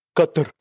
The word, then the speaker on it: Qatar: Tour of Qatar.